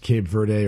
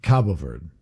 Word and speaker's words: Cabo Verde: It's pronounced Cape Verde or Cape Verde, but the Portuguese pronunciation is Cabo Verde.